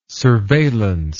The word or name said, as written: surveillance